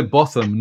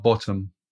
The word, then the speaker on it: Botham: So you say Botham, not Bottom.